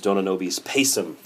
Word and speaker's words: Dona Nobis Pacem: So Vaughan Williams also said these verses, and they were included in his Dona Nobis Pacem, as I prefer to pronounce it. The people that just left and entered the room, had entered and left the room, were just scoffing at my pronunciation. – Pacem, but that's, you know, if you're going to use English, and it's an English work, you might as well pronounce it.